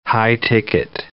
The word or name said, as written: high-ticket